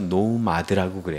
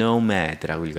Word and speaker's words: nomad: 그 사람들이 딴 나라, 말레이시아나 이런 데 또는 아프리카 이런 데 고급 인력으로 가는 거, 그거를 유목민처럼 간다라고 해서 노마드라고 그래요. 물론 발음은 영어식으로 할 때는 Nomad라고 읽어야 되겠지만 신문에서는 노마드라고 그냥 써요.